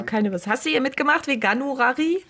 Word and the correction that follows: Veganuari: Kannst du gar nicht aussprechen. Veganuari? – Nee.